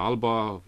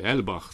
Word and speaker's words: Alba: Nu se pronunță Alba, pronunția este celtică, e Elbach, sau oricum ceva foarte, foarte greu de pronunțat de către noi, care difuzează cam 4 sau 5 ore doar programe în limba scoțiană, în gaelica scoțiană.